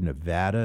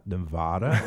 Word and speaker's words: Nevada: By the way, I learned how to say Nevada and not Nevada, which they will tell you about right away if you say it wrong. – Nevada.